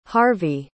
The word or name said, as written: harvy